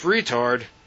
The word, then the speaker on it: Retard: "Joseph Retard? What the heck kind of name is Retard?" "It's French-Canadian, sir, and it's pronounced retard with the accent on the second syllable."